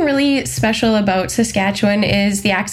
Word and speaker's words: Saskatchewan: Just say Sask. I'm going to have to write it phonetically in front of me. Saskatchewan. Yeah, that's it.